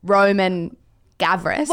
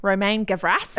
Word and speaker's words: Romain Gavras: We kind of have towed off mic for the last 5 minutes if we do the bogan Australian pronunciation of Roman Gavras. – Well, you looked at me and you're like, how do we say this? I'm like, Romain Gavras.